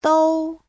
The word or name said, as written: dou1